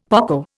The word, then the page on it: poco: Indian